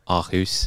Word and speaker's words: Aarhus: So the place I actually went in Denmark, which is, I think I'm pronouncing this correctly, Aarhus. – I'd say that is, for an ignorant Englishman, a decent pronunciation of Aarhus.